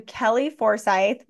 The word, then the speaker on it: Kellie Forsyth: You can go to Kellie Forsyth. My last name, well, first and last name is spelled unique. Kellie is K-E-L-L-I-E, last name Forsyth, F-O-R-S-Y-T-H.